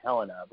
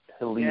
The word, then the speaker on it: Helena: They actually pronounce it Helena, but Helena.